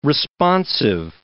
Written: responsive

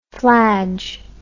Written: fladge

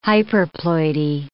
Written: hyperploidy

